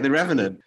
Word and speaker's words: The Revenant: Did I say it right? Because I... – You did. You don't have to think about it. You say it right, The Revenant.